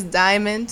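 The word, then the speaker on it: Dymond: My real name is Diamond, spelled with a Y. D-Y-M-O-N-D.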